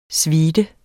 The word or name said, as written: suite